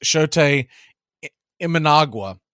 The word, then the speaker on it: Shota Imanaga: Shota Imanaga.